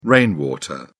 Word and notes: rainwater: British English

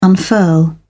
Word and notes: unfurl: British English

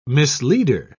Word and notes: misleader: British English

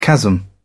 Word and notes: chasm: British English

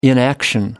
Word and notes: inaction: American English

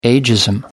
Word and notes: ageism: British English

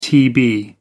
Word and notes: tb: American English

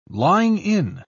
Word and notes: lying-in: British English

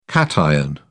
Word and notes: cation: British English

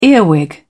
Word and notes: earwig: British English

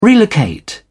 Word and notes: relocate: British English

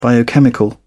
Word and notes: biochemical: British English

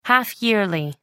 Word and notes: half-yearly: American English

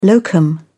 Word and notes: locum: British English

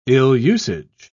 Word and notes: ill-usage: British English

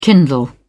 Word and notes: kindle: British English

